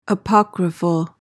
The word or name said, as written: apocryphal